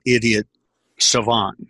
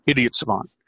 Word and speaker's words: idiot savant: And I'm when it comes to scripting and sales letters, I'm an idiot savant. And Mitchell loves it when I say that because I have to say it together real quick, not idiot savant. It's idiot savant.